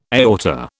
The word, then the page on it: aorta: British English